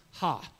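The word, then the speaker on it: ha (ὁ): The article in Greek is this little O with this is a rough breathing mark above it, okay? Pronounced ha, okay?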